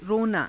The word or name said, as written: ronaa